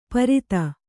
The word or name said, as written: ಪರೀತ